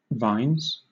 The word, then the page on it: vines: British English